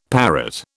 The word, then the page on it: parrot: British English